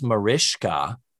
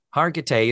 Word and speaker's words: Mariska Hargitay: It is Mariska with a shush sound in there. Hargitay,